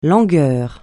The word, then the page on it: langueur: francais